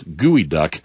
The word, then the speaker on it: geoduck: Also tried for the first time gooey duck, spelled G-E-O-D-U-C-K, an odd spelling. It looks like it should be pronounced geoduck, but it's gooey duck.